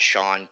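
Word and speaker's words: Shawn: So, and that's at Sean K. Rooker. – And last name, first name is spelled S-H-A-W-N, K as in kangaroo, Rooker, R-O-O-K-E-R.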